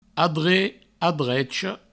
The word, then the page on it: adrech, adrecha: adre; adrecho